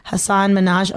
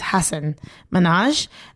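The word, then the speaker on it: Hasan Minhaj: There's also like this thing of like people don't get like non-white names properly, like Hassan Minhaj. Oh boy, I think I said his name wrong though. It's Hassan Minhaj.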